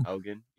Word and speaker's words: Haugen: Are we pronouncing it Haugen? Hogan? – Haugen, yeah.